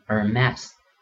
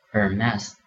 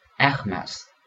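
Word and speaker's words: Hermès: 那同这个路易威登一样比较难读的另一个呃法国奢侈品品牌呢是爱马仕，H E R M E S，读成 Hermes，这里的它的 H 不发音。因为法语里面呢，这个 h 在词首的时候要不发音。那美国人呢通常读成 Hermes，那法国人读成呢是 Hermes。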